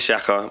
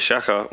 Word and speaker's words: Shakka: My name is Shaka. My name is Shaka with two Ks. It's spelled S-H-A-K-K-A.